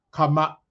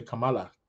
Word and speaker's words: Kamala: who's correcting me that her name is pronounced Kamala. Sorry. See, I can't even say it. Kamala Harris. Okay. I keep wanting to say Camilla, but it is Kamala.